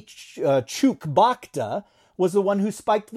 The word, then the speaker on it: Chewbacca: Chewbacca.